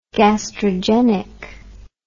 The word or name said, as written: gastrogenic